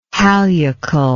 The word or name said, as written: hallucal